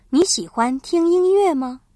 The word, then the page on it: 喜欢: xǐhuan